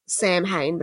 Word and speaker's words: Samhain: So that's spelt Samhain, but it's pronounced Samhain.